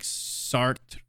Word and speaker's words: Sartre: Which is a lot of French philosophers are involved in it, like Sartre, if you know his name. I think I pronounced that correctly. – Yes, yes, Georges Sartre, yeah.